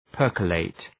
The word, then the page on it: percolate: ‘pɜ:rkə,leıt